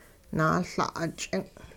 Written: naaL'a'-ch'ing'

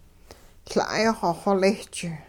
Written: La'ay-xw xolisch

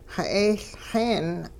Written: xe'eeLxeen

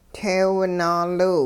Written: teew naaliw

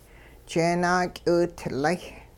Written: jeenaak'iwitlay

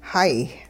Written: hay

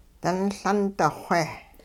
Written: dunLundi-xwe

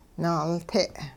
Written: naalte'

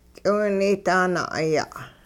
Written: k'iwunaydaa-na'aya'

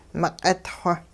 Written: meq'it xwo

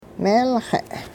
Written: meelxe'

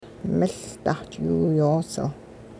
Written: miLdahch'iwiyoosil

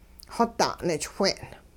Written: xoda' nichwin

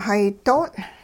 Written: hay do'ong'

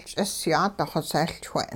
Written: ch'isyaat xosehLchwe'n

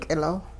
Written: k'ilo

